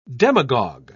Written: demagogue

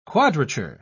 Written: quadrature

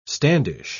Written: standish

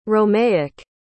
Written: romaic

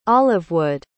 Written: olivewood

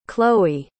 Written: chloë

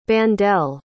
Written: bandele

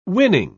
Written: winning

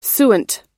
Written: suint

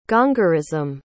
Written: gongorism

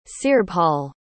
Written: cearbhall

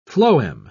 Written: phloem

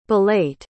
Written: belate

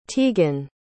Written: teigan

